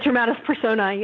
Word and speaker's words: Dramatis Personae: Now, you have in the book in the very, very beginning, how do you pronounce this, Dramatis Personae? – Dramatis Personae, yeah, the character list.